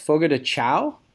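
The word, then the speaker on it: Fogo de Chão: I'm gonna butcher the pronunciation of this, but eating at Fogo de Chao.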